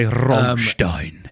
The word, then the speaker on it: Rammstein: No, but, I say Rammstein, and then I say it in that voice that's how they say it.